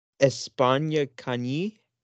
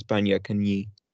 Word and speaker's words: España Cañí: España Cañí. Is that how it's pronounced? – How's it spelled? – I mean, each N and each word has a tilde above it. So I assume because that's why pronunciation. I think it's España Cañí. Or has an I has a dash over it, so it'd be Kenya.